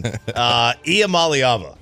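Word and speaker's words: Iamaleava: How do you pronounce the last name? I'm a leaving. – I am a leaving. Iamaliava. I pronounce that I am a believer.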